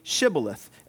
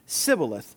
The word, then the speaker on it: Shibboleth: When he said, "No," they said to him, "Then say Shibboleth." And he said, "Sibboleth," for they could not pronounce it right.